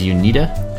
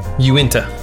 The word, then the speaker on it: Uinta: And this is, we had to look up how to pronounce this, and you said it was Unita? – Uinta. – Know how to pronounce this. – Uinta.